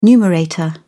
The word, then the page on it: numerator: British English